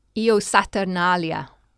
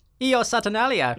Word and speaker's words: Io Saturnalia: Io Saturnalia. – Saturnalia. – Right. Okay. It changes every time with me. – Io Saturnalia, yeah.